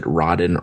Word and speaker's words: Raden: Is it Rodden or Raiden? R-A-D-E-N. – Rodden. I say Rodden. – I say Rodden. – Sounds fancier anyway than Raiden. Mm-hmm. – Raiden doesn't sound right.